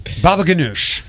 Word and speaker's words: baba ganoush: Baba ganoush.